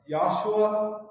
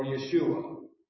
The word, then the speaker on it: Yeshua: That also was extremely offensive for the Jews of the day, because they were like, well, that guy, Yahshua, is the way they would pronounce it. Yahshua, or Yeshua.